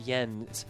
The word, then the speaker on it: Jens: I have actual Swedish friends that confirm that the name is pronounced Jens, but he pronounces it Jens as a sort of Anglophile because he's really influenced by like Scott Walker and Morrissey and bands like that.